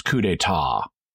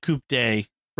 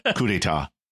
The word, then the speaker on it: coup d'état: cannot pronounce coup d'état, and it's just so awkward and lame the way that line gets delivered and is written. – Right? Yeah. No. No stupid coup day. Right. It's French.